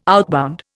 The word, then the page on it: outbound: Indian